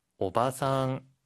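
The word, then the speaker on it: 오바상 (おばさん): 일본어에는 장음이 있어요. 그래서 길게 발음하고 짧게 발음하는 거에 따라서 의미가 달라지는데요. 지금 아저씨는 오지상. 길게 해서 오지상이라고 하면 할아버지. 그리고 오바상, 짧게 하면 아주머니. 길게 오바상이라고 하면 할머니가 됩니다. 여러분, 장단 꼭 주의하세요.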